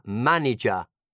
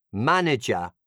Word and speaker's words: manager: Sentirai la parola manager. Manager, which is, non è manager.